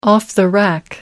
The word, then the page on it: off-the-rack: American English